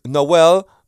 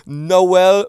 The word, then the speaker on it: Noel: My name is Noel Michael Smith. Two syllables, no L. Yeah? Noel Michael Smith.